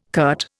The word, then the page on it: curt: Indian